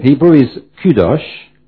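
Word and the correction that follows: kadosh (קדוש): L'hébreu c'est kadosh en fait. – Oh, kadosh. I turn to my friend Samuel for the right pronunciation.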